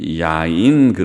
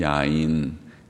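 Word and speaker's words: 야인 (יין): 포도주를 히브리 말로는 야인 그렇게 말하는데 야인, 야인이라는 단어인데 야인이라는 말에서 영어의 와인이라는 말이 나온 것 같아요. 발음이 비슷합니다. 야인, 와인.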